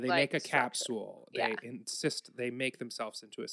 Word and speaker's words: encyst: They travel to striated muscles where they insist. – They insist. – It's spelled E-N-C-Y-S-T. This is another thing, like seeing it written, I'm like, oh, it makes sense. But saying it out loud.